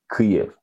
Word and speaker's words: Kyiv: In Ukrainian, you would say like Kyiv. Yeah. – Okay. – Kyiv. Yeah. – All right. So anyway, that's the one. So it is two syllables.